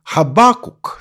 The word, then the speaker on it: Хабакук: Во многих других национальных переводах Библии «Хабакук», собственно, так и остается. Ударение действительно делается на второй слог, и последний звук «к».